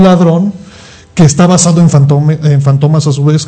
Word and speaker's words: Fantomas: Está basado en el personaje francés Fantomas, así se pronuncia.